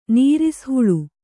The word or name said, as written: ನೀರಿಸ್‍ಹುಳು